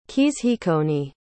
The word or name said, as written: keezheekoni